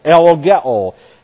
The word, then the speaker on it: ellogeo: This is E-L-L-O-G-E-O, long O on the end. Elogeo.